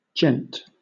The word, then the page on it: gent: British English